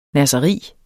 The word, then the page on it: nasseri: ˈnasʌˈʁiˀ